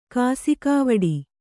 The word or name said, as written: ಕಾಸಿಕಾವಡಿ